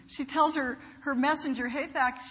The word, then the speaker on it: Hathach: But thankfully, Hathach, and I looked up how to say it, it's Hathach, the messenger, he conveys what's going on back and forth, and you had that sort of passage.